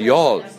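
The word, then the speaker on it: Youghal: Would you know how that is actually pronounced? – Yughal. – Right. – It is Yughal. – And if you call it Yughal, which rhymes with Google, Irish people will wet themselves laughing, right? – Okay. – It's called Yughal.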